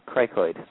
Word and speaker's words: cricoid: The fracture of the cricoid, I hope I'm pronouncing that correctly.